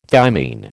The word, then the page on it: thymine: American English